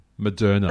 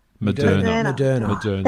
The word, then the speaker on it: Moderna: Is it Moderna? – Moderna. – The Moderna? – Moderna. – Thank you. Thank you for helping with that. Moderna becomes available this week.